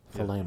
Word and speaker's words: Thelema: T-H-E-L-E-M-A. – Thelema. – Thelema.